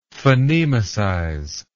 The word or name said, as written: phonemicize